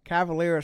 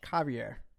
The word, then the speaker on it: Cavalier: Cavalier is not how you actually pronounce it. – How do you pronounce it? – It's actually pronounced Cavalier. It's a French name from back home.